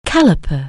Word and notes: calliper: anglais

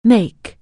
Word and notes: make: anglais

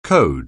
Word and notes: code: anglais

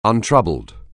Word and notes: untroubled: anglais

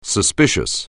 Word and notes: suspicious: anglais

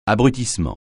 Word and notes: abrutissement: francais

